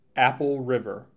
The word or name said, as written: Apple River